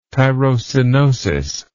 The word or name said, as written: tyrosinosis